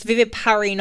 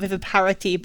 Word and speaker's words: viviparity: Yeah, so I'm saying it wrong. It's viviparity, not viviparity. But yeah, go and look up viviparity tomatoes.